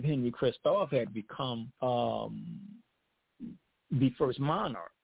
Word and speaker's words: Henri Christophe: The other lesser known figure, Henri Christophe, you know, the French, you know, will pronounce his name as Henri Christophe.